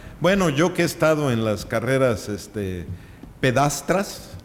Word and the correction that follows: pedestres: no, ¿cómo se dice? – Pedestres. – Me falló una letra por ahí que significa otra cosa, pero va a haber cochinita.